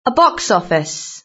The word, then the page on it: a box office: British English